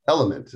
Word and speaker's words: LMNT: And this is Element. It's a tasty electrolyte drink. By the way, that's spelled L-M-N-T.